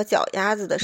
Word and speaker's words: 脚丫子: 老公还喜欢让我闻他的脚丫子，说屁眼子和脚丫子的时候，还得特意把屁眼子和鸭子这两个子字读成杂的轻音，就是屁眼子、脚丫子。